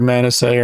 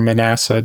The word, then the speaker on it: Manasseh: Could be either Manasseh or Manasseh, depending on how you feel like pronouncing it. It's probably Manasseh.